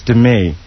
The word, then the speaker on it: Demi: She goes, it's Demi. And I said, oh, I'm sorry. She goes, no problem. Just needed to correct it. – Demi. – The accent is on the me.